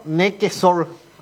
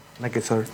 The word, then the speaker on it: Nequesor: Sí, Luciano Nequesor, así se lee. – Sí, estaban debatiendo en el grupo de cómo se escribe el nombre, sí. Cómo se pronuncia. ¿Eh? ¿Cómo es? – Nequesor.